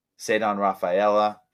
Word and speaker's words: Ceddanne Rafaela: Ceddanne Rafaela, yes, thank you.